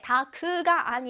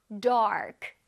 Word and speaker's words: dark: 다크라고 우리 친구들 알고 있을 텐데 다크가 아니라 AR 발음을 정확하게 해줄게요. dark, dark.